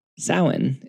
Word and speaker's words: Samhain: we have to know the history of its precursor, Samhain, that funny-looking word that looks like it should be pronounced Samhain. Samhain is a festival celebrated in Celtic paganism and Wicca.